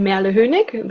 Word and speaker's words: Merle Hoenig: Hi, so I'm Merle Hoenig. This is the pronunciation of my name.